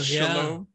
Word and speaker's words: Shalom: Shalom.